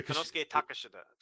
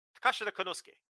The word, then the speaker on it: Konosuke Takeshita: It's Konosuke Takeshita. Wait. Konosuke Takeshita. Takeshita Konosuke. – Okay. – Takeshita Konosuke. Like that is how you're supposed to say it.